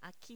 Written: aqui

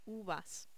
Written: uvas

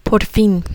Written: por fin

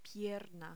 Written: pierna

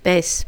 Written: vez

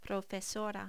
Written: profesora

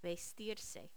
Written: vestirse

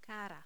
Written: cara

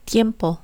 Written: tiempo

